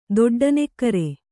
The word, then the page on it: ದೊಡ್ಡನೆಕ್ಕರೆ: doḍḍa nekkare